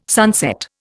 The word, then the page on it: sunset: Indian